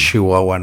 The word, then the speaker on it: Chihuahua: es un meme muy conocido en México de que pronunciamos mucho la sh. – Es que lo pronunciamos más como una SH, ¿no? Decimos mm. Chihuahua, ¿no?